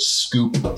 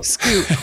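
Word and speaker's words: Scúp: Like, I want to ask about Scup. – Scup. – Is that how you say it? – Oh, Jesus. – 2013. – That's an Irish language TV show called Scoop. – Scoop.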